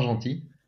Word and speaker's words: Gentil: donc je m'appelle Valentin Gentil. Alors Gentil c'est mon vrai nom de famille. Ça s'écrit l'opposé de méchant, comme ça, comme ça on retient.